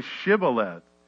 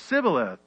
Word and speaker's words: Shibboleth: If he said no, then he would say to him, then say Shibboleth. And he would say Sibboleth, for he could not pronounce it right.